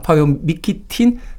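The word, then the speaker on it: Paweł Mykietyn: 이 이름 발음하기가 쉽지가 않아서 좀 찾아봤는데 파베오 미키틴이라고 발음을 하는 게 맞다라고 합니다. 파베오 미키틴 작곡의 The Beginning 듣습니다.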